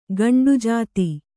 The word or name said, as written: ಗಂಡುಜಾತಿ